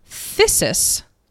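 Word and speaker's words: phthisis: P-T-H. – Oh, it's thesis. That's it. Hold on. Thesis, which Dr. McElroy taught me is pronounced thesis and not thesis, is a Greek word for consumption.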